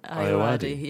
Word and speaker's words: Ayoade: Ayoade.